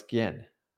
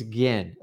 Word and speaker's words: Gin: It's Gin. It's a hard G.